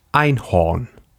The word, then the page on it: Einhorn: /aɪnhoːrn/